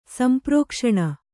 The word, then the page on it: ಸಂಪ್ರೋಕ್ಷಣ: kannada